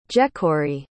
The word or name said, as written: jecori